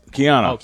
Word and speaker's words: Keanu: I think of Canal the Matrix guy, Canal Reeves, right? Am I saying his name right? Keanu Reeves? – Keanu Reeves. – Keanu. That's the way you say Keanu.